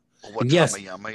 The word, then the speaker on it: Wembanyama: Wembanyama, yeah.